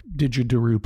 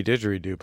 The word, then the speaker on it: didgeridoo: But your concern is that the itch will go away and you'll move on to another hobby, like didgeridoo playing, or I don't know. What are some hobbies? – I mean, primarily didgeridoo playing. – Didgeridoo. Yeah, sorry, I said it wrong.